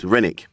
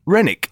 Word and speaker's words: Renwick: How would you say that name? – It's Renick. – Renick, isn't it? Yeah. Stephen Renick. Let's try that again. – I mean, okay. He's a silent W, yeah.